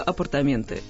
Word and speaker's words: апартаменты: Для нас же в данном случае главное ударение. Как выясняется, лучше апартаментов могут быть только апартаменты.